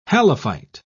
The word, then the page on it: halophyte: British English